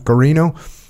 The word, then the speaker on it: Guarino: Major Larry Guarino, am I saying that right? – Guarino,